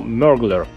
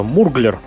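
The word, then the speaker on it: Murglar: Называется оно «Мерглер». Ну, для тех, кто не приучен правильно произносить английские слова, могут его называть на русский манер очень просто «Мурглер». Оно так и пишется «Мурглер».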